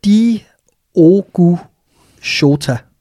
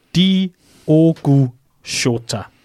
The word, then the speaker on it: Diogo Jota: Lad os, lad os lige få noget øh, udtaleservice på her, fordi øh, du, du læste et sted, hvordan man skulle udtale det ordentligt. Diogo Jota. Mm-hmm. Diogo Jota.